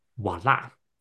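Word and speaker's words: voilà: 其实英文里面有很多的单字，看什么时候创的，很多的单字是从法文来的。所以，通常如果你有看到 OI 这种的组合，by chances it's probably not English，很大几率呢，它不会是英文，那就可能是法文。But in French，OI it's pronounced as wa。可是，如果在法文里面呢，你看到 OI 的话呢，它的发音会是哇的音，而它会是哇的发音。So yeah, so that's why we say voila, voila.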